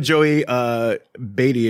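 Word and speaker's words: Joey Batey: Joey Beatty is, I believe, how you pronounce it.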